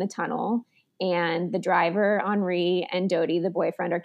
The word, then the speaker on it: Henri: The decoy car left, and this guy named Henri, which is really Henry, but pronounced Henri because Paris European.